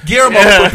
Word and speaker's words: Guillermo: Amanda Plummer, Bokeem Woodbine, Guerrilla Diaz from Half Baked. Really? – Guillermo for people that can pronounce it.